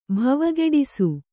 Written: ಭವಗೆಡಿಸು

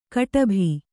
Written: ಗೊಡಬೆ

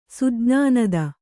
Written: ಸುಜ್ಞಾನದ

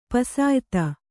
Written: ಪುಸಿಯೋತ

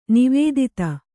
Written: ನಿವೇದಿತ

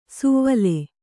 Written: ಸುವ್ವಲೆ

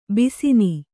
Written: ಬೇಸಿನ್

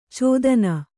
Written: ಛೇದನ

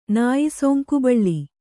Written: ನಾಯಿಸೊಂಕುಬಳ್ಳಿ

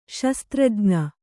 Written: ಶಸ್ತ್ರಜ್ಞ

